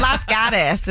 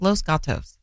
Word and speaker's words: Los Gatos: Las Gatas is how we pronounced it. – The what? – Las Gatas. It's Los Gatos.